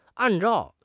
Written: an4 zhao4

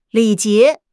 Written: 礼节

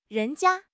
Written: 人家

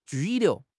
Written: 拘留

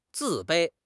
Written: 自卑

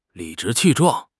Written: 理直气壮

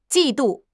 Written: 季度

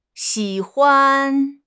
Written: xi3 huan5

